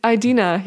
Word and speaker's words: Idina: Idinza ah, eh, eh, Menzel, weet je? Oh, Idina, ja.